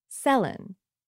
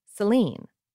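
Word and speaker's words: Céline: Uh, when she was born, Madame Dion pronounced her daughter's name Selin. Kids in school pronounced it Selin. Everyone around pronounced it Selin. But when she got popular in English Canada and the States, everyone pronounced her name Celine.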